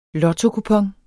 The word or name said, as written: lottokupon